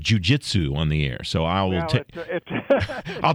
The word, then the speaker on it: jujitsu: Jujitsu. – Jujitsu.